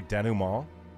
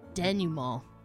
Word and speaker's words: denouement: I pronounce it. You might say it differently. If I say denouement. – I say denouement. – You guys can't even say it. – Long A. Denouement.